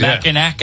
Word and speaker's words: Mackinac: It's spelled, why do they spell it Mackinac when it's Mackinac?